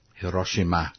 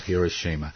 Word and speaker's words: Hiroshima: Hiroshima. – Yes. Not Hiroshima.